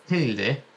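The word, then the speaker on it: Hjelde: Lewis Bate and Leo Heldy. Am I saying this right? Who can give me a tuition on that? – And it got a hum behind it. – Hilde and Leo Hilde made their debuts for the club, while Sam Greenwood also started his first game.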